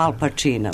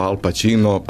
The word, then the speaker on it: Al Pacino: italijansko slovo C, odnosno udvojeno CC, nađe ispred samoglasnika E, odnosno I, izgovara se kao naše Č.